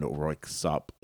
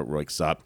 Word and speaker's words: Röyksopp: But I did a little research, and I think there's two ways to pronounce it. Royksopp with the K, or you don't pronounce the K.